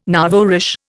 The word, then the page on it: nouveau riche: Indian